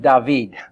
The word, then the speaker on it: David: And the fact that he's the son of David. Oh my. Or as they would have pronounced it back then, David. David.